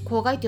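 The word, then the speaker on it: 香害: 公害についてですね、え香りの害という漢字を書いて公害と読む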